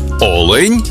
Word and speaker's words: олень: в однині, і в множині наголос падає на перший склад. Олень та олені.